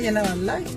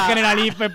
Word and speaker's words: Generalife: Y cuando estamos yendo, siguen mirándolo y tal, y dice, sí, es que solo pone General Life y tal. Y dice la otra, ¿será que se pronuncia Generalife? – ¿Será que se pronuncia Generalife? Pues será, efectivamente, será. En fin,